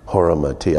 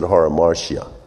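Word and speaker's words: hamartia: Haramatia. I would have said haramartia, but it's haramatia.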